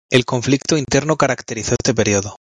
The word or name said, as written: interno